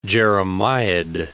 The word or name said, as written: jeremiad